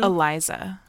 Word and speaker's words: Eliza Dushku: You said Elijah Dushku. – Yeah, I believe that's how you say it, Elijah Dushku. – Okay, I thought I heard you wrong. – Elisa Dushku. – Eliza. – Eliza.